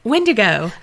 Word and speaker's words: Wendigo: The word rhymes with indigo, but we don't say it, and don't you dare think it. – I've already thought it. – What the hell is it? – Wendigo.